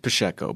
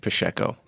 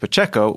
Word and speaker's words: Pacheco: Well, I was raised pronouncing it Pacheco, and I'll explain why. So my grandpa was from southern Brazil, and in the Portuguese language, the CHs are pronounced like an SH.